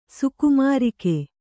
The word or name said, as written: ಸುಕುಮಾರಕ